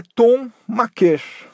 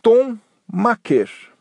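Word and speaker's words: Thon Maker: E ele esclareceu que a pronúncia correta do sobrenome dele, segundo o próprio jogador, é Tom Maker. Tom Maker. E não Maker né, até meio óbvio né, que não seja maker.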